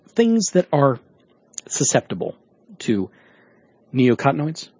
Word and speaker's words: neonicotinoids: Neonicotinoids. – Neonicotinoids. – Thank you. – Neonicotinoids.